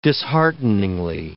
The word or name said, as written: dishearteningly